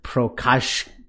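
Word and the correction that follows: Procházka: Uh, Prochazka.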